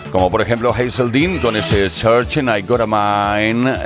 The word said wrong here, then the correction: I Gotta Find a Man: eh, lo he dicho mal, I Gotta Find a Man, ahora sí, eh, 130 BPMs de energía total.